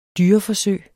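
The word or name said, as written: DYREFORSØG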